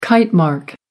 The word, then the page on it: kitemark: American English